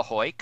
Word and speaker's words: Hoyk: I am on Twitter at @THE_HOYK, The Hoyk, a phonetic pronunciation of my name.